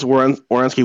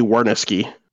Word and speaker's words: Werenski: Like he still calls Werenski Werniski. Yeah.